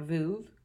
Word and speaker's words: Veuve: A Veuve.